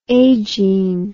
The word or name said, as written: agene